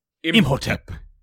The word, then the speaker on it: Imhotep: Make sure you say it properly. They never in these movies say an Egyptian word without being dramatic. Imhotep. Yes, very good.